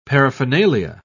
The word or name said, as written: paraphernalia